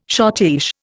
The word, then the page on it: schottische: Indian